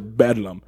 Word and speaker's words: Bedlam: They would pronounce it Bedlam. – Mm-hmm. So instead of Bethlehem, it was Bedlam.